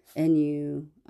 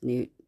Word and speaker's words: Nuit: And then just before I deleted the Nuit app, Nuit, I think it's actually pronounced Newt.